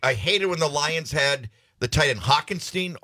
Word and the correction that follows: Hockenson: How do you pronounce his last name? – Hockenson. – Hockenson.